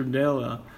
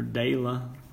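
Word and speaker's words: Daella: Della. Or Dela how you want to pronounce it. Dela. Yeah,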